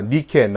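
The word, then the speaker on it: 니케 (Νίκη): 니케, 나이키라는 상표 아시죠? 나이키라는 상표의 뜻이 승리라는 뜻입니다. 그리스 말로는 나이키라고 읽지 않고요. 니케라고 읽습니다.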